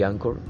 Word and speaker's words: Anchor: No, aquí estoy con mi teléfono y una aplicación muy sencilla que se llama Anchor o Anchor. Anchor como se escribe y Anchor como se pronuncia.